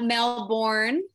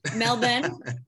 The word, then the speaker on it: Melbourne: I have been to Melbourne. See how I pronounce that? Not Melbourne, Melbourne.